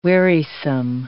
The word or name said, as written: wearisome